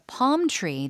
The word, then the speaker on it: palm: P-A-L-M인데 발음할 때 palm tree 발음 다시 한 번요. – Palm tree. 약간 길게. L 발음이 나지 않는다고 생각하시고 그렇죠. 그냥 길게 palm tree.